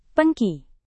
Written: pengki

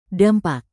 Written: dampak

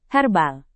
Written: herbal

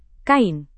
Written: kain

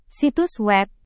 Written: situs web

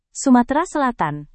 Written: sumatera selatan

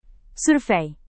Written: survei

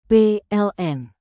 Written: PLN

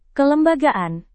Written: kelembagaan

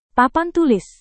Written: papan tulis

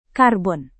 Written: karbon

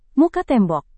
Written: muka tembok

